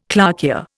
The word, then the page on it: clarkia: Indian